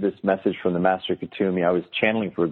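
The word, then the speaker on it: Kuthumi: Is that how you pronounce it? – Yes, Kutumi.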